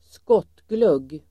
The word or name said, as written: skottglugg